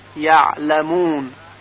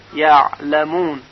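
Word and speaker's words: يعلمون: و همچنین حرف عین در کلمه یعلمون، یعلمون.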